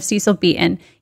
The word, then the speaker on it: Beaton: And Richard says, you know that Marilyn was a very intimate friend of Cecil Beaton? Did I say that right? – Mm-hmm. – Yeah? – Yeah, there you go. – Okay. A very intimate friend of Cecil Beaton.